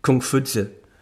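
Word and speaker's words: Kong Fuzi: Confucius is a corruption, really, of the name Kung Fu Tzu. It wouldn't have been pronounced Confucius in China, 600 BC. It's Kung Fu Tzu.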